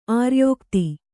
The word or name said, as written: ಆರ್ಯೋಕ್ತಿ